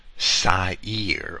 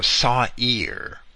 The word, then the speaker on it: Sa'ir: It's spelled basically S-apostrophe-Y-R. If I had to change the letters to English letters, Sa'ir or Sa'ir, as Strong's transliterates it.